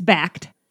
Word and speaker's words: Bakkt: We don't know when the name change is officially taking place, but Backed is B-A-K-K-T, by the way. And already people on Twitter are like, how do you say it? – Well, people on the news have been getting it wrong, too. – They sure have. We were kind of laughing. We're like, we did one Google search and we found out how to say it. It's Backed.